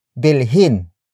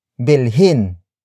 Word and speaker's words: bilhin: Another example, bilhin, bilhin, bilhin, bilhin is spelled as B I L H I N.